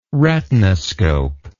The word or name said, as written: retinoscope